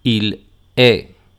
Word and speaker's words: il est: Por ejemplo, tu e il se pronuncian igual. Tu es, il est, esas S y la T no suenan.